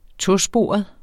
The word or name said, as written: tosporet